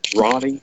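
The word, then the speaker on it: Roney: My name is spelled like money with an R, Ronnie Smith, RoneySmith.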